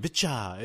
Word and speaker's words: VCHA: VCHA. – It's VCHA. – Yeah. – So VCHA is out.